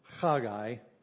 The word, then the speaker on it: Haggai: Haggai, in Hebrew you'd actually pronounce his name Haggai, and his name is related to the word for feast.